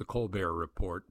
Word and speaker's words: Colbert Report: His previous experience, The Colbert Report, pronounced as though on TV Francais, on Comedy Central, was a never-ending sketch which had Mr. Colbert playing a caricature of a self-obsessed, blowhard conservative commentator.